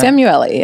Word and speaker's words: Samueli: Samueli, yes.